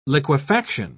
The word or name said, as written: liquefaction